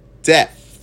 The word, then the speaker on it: depth: depth. How do you say that? – In depth. – Okay. – Depth. Like a P and then a TH.